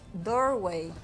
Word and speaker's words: Doorway: No es Durway, es Doorway.